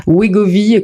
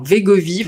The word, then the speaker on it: Wegovy: C'est un W, oh, vous le prononcez comme vous voulez, Wegovy, comme le wagon ou le wagon, comme bon, alors Wegovy, voilà.